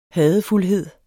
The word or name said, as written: hadefuldhed